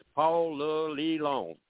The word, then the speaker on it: Ponce de Leon: Only a native can pronounce Ponce de Leon, so do not attempt the French pronunciation. People will simply tilt their heads to the right and stare if you do. The Atlanta pronunciation is Paul Le Leon.